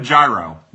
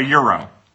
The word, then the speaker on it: gyro: Kyle, baby, it's pronounced Yero. I'm an idiot. But look, I'm not to be held accountable. At Arby's, they call it a gyro. They call it a Euro.